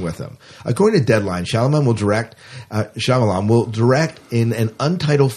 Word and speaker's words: Shyamalan: Night Shyamalan? – Shyamalan, okay, whatever.